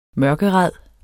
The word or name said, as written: mørkeræd